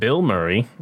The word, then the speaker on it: Bilmuri: A band that I really like, named Bill Murray, which is funny all on its own, but it's spelled completely different. B-I-L-M-U-R-I.